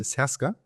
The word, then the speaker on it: Serbska: Serbska Utopia, genau, das B spricht man nicht, aber nochmal, wie? – Serbska.